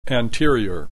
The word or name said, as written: anterior